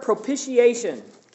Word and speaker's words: propitiation: Big word, propitiation. Can't even say it. I've always had trouble. Propitiation is how you say that word.